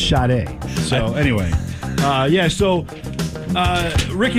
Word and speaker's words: Sade: It's, of course, Sade, who for many years I pronounced with an R in her name, and there's no R. – You. – Sade. It's not Sade.